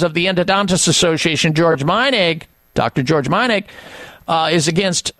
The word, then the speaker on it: Meinig: Root Canal Cover-Up by Dr. George Meinig. He's a doctor of dental surgery, and his last name is spelled M-E-I-N-I-G.